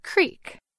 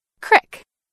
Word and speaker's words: creek: Most Americans say this word as creek, with a long E sound, like in the word beat. But in some parts of Appalachia, like western Pennsylvania, West Virginia, and the Ohio Valley, you might hear this word with an I sound, as in crick, like in the word tip.